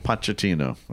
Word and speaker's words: Pochettino: How do you pronounce his name? – Pochettino.